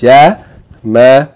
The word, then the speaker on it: جَمَلٌ: Short vowel, short vowel, tanween. 3 syllables. جَمَلٌ. It sounds close to the English word that comes from it. Jamalun sounds close to camel. Camel. The English word camel comes from the Arabic word jamal.